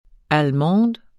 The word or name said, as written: tysk